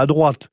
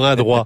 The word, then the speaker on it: Adroit: Oui, alors je suis étonné, parce que vous qui prononcez surtout les lettres finales, sans faire de politique, vous avez bien dit Adroit et pas à droite.